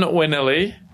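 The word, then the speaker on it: Bronn Wennili: Bron Winilly. Bron, B-R-O-N-N. – It looks legit. – Yeah. Winilly, W-E-N-N-I-L-I, Winilly.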